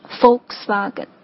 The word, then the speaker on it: Volkswagen: 因为德语的这个 V 的这个音呢，在英文当中应该是发成这个字母 F 的这个音的，所以正确的发音哈，真正懂车的人的发音应该是 Volkswagen，第一音节就是 Volks 这个单词的发音。